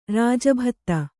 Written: ರಾಜಭತ್ತ

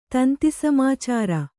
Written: ತಂತಿಸಮಾಚಾರ

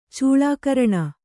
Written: ಚೂಳಾಕರಣ

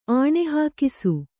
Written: ಆಣೆಹಾಕಿಸು

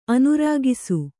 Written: ಆನೆರಕ್ಕಸ

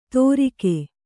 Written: ತೋರಿಕೆ

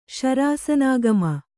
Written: ಶರಾಸನಾಗಮ

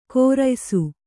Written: ಕೋರಯ್ಸು

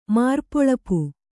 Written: ಮಾರ್ಪೊಳಪು

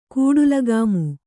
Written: ಕುಟಿಲಗಾಮಿ

